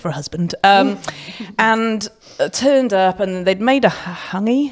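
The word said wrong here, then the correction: hangi: Hopefully I've said that right. And I'm not very good at my pronunciation in hangy. – Hangy. Yeah. Okay, cool.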